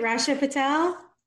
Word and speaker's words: Rachna Patel: Rachna Patel? – Rachna Patel.